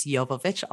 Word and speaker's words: Yovovich: So if you want to find me on LinkedIn, it's Rada, R-A-D-A. And my last name is Yovovich. I'll spell it Y-O-V as in Victor, O-V as in Victor, I-C-H.